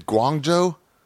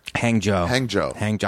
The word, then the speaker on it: Hangzhou: So you landed in Guangzhou? Where? – Hangzhou.